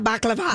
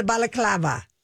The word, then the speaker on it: balaclava: Well, I call it like a baklava. – Baklava, which is, you know, the good dessert. But it's it's a balaclava. I think I'm saying that right.